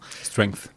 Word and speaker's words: Strength: qui est notamment popularisé par Sean, Upside, Upside Strange, de la chaîne Upside, Strange, je la prononce pas bien. Strength.